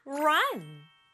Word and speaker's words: run: 친구들이 R 소리로 시작할 때는요, 입이 좀 동그랗게 되고 여기 힘이 들어가야 돼요. run. 이 소리가 R의 소리거든요. 그리고 소리 나는 you 그리고 붙여서 run, run, run 요렇게 발음이요.